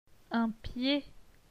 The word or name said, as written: Un pied